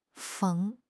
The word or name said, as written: 逢